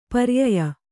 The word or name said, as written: ಪರ್ಯಯ